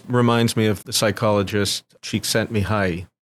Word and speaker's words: Csikszentmihalyi: So Csikszentmihalyi, I had to practice to pronounce that amazing name. and you got it right, by the way.